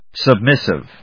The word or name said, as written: submissive